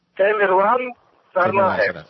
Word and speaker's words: Tamerlan Tsarnaev: Tamerlan Tsarnaev.